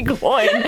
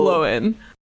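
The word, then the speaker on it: Glóin: So a slight um, argument in favor of Gloin not being the correct pronunciation is that Gloin has a brother whose name is just O I N, and I think that's usually pronounced Owen. So I would oh. think this would be oh. Gloin. Yeah, I, I like that it. as an argument.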